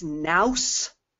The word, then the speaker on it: nous: something new, and he also had funny accents, and I thought, okay, that's, it must be new. Okay, it's not. It's nous, like house. Rhymes with house, and it means being dim-witted.